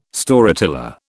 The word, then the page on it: Storyteller: British English